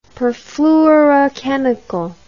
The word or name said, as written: perfluorochemical